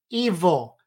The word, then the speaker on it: EVL: Padilla Rodriguez untangled some of the mysteries of a protein called EVL, pronounced evil.